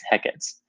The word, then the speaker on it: Hecate: And in the early modern English language, the name was also pronounced dissyllabically as Hecate, and sometimes spelled H-E-C-A-T. It remained common practice in English to pronounce her name in two syllables, even when applied with a final E, well into the 19th century.